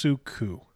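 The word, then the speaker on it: Soukoo: It's spelled S-O-U-K-O-O. I think it's pronounced Suku.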